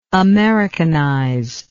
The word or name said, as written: americanizer